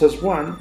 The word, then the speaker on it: Aswang: Se escribe así, A-S-W-A-N-G, Aswang o Aswang.